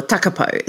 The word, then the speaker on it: Takapau: Like as a child, we lived in a little town south of Hastings called Takapau, or Takapo is how it's properly pronounced.